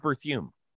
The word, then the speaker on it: Berthiaume: Steve Berthiaume.